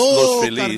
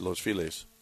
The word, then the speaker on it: Los Feliz: And it's no, Los Feliz, but they pronounce it. Everybody here mispronounces it Los Feliz.